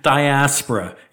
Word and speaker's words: diaspora: And that's Disparia. – Diaspora is the word, Ronan.